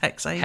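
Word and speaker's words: Hexe: Is it actually pronounced Hexay? – Hexay, yes.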